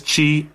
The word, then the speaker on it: chi: In the Greek language, the alphabet, the letter that looks like an X is Greek letter for C-H-I, pronounced chi. It rhymes with I, which is the first letter of the Greek word for Christ.